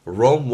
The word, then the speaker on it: Rome: Rome wasn't built in a day. 제가 먼저 한번 해봤습니다. 여러분도 한 번씩 내뱉어 보실 건데요. Rome이라고 발음하시기보다는 Rome, Rome 중간에 우 사운드 있다라는 거.